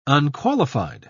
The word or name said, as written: unqualified